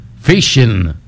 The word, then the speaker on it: phishing: Phishing. ¿Lo dije bien? Phishing. – Sí, phishing.